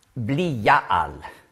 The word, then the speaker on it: Belial: Das hebräische Wort Belial spricht man aus Bli-Ja-Al.